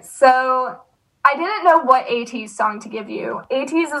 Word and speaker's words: ATEEZ: Is that how you say it? ATEEZ? Mm-hmm.